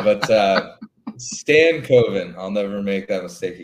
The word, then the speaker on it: Stankoven: We got to get you to pronounce that properly. He's on your team, for crying out loud. Stankoven. There you go. – Stankoven.